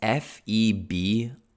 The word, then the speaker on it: February: So I want to point out that February has a weird pronunciation, or maybe it has a weird spelling. It's February. Why is there an R after the B? People usually don't say February, but there is an R.